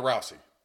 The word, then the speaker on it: Rousey: But when it comes to sporting events, you wouldn't put Mike Tyson in the ring with Ronda Rousey. All right, that's how you pronounce her last name.